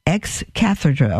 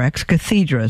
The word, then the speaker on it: ex cathedra: All it does is say that when the Pope speaks ex cathedra, or ex cathedra, is pronounced both ways,